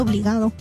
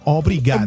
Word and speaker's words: obrigado: Porque van a pensar que estás diciendo obligado. – No, obrigado.